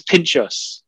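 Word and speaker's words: pintxos: Now, how do you pronounce that? Pinchos? – I believe it's Pinchos. That's a Basque country thing in Spain. Not that I'm any sort of expert on that, but I think that's how you pronounce it, Pinchos.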